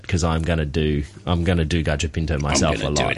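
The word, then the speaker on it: gallo pinto: If you're going to search for it, it's actually spelt gallo, so G-O-L-L-O, but it's pronounced gajo pinto, and then P-I-N-T-O, pinto meaning beans in Spanish.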